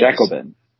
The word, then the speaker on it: Jacobin: The first syllable is where the emphasis comes in. – It is. – Jacobin.